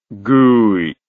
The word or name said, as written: GUI